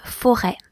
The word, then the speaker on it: forêt: The verb to be is être, E accent circonflexe, T-R-E. Être. There's also the word une forêt, F-O-R-E, accent circonflexe, T. Une forêt, that's a forest.